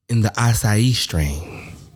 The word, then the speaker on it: acai: The acai.